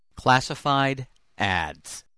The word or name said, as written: classified ads